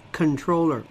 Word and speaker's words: comptroller: Controller is the pronunciation of comptroller. It's controller. Mischievous?